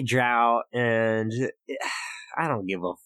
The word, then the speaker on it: drow: It's drow elf. It's got a D-R-O-W, right? – Yeah. – Yeah, that's drow. – It's drow.